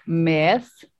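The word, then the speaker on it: Með: So it's Meth. – Almost like med. – Med, like med. – Med. Med, yeah.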